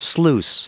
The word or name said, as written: sluice